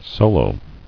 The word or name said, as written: Solos